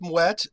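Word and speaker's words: Moët: Mouette, the company pronounces it mouette. It's the official supplier to the Queen of England. I imagine Queen Elizabeth says mouette. I think we should probably stick with mouette in this case.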